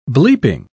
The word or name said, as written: bleeping